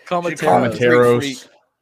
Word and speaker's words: Komateros: Anything else on Tyrin Lawrence, Prince Collie, Tomas Komata? How do you pronounce that? I keep forgetting how to pronounce it. I'm going to call him the Greek freak. – Komateros. Tomas Komateros.